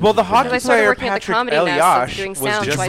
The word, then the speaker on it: Elias: I mispronounced Joey Elias's name. – What did you say? – Eli. – Elias? – Elias. – Elias, yeah.